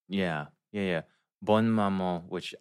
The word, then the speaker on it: Bonne Maman: Is it Bonne Maman? Bon Maman? – Oh, Bon Maman. It's the French one with the checker top.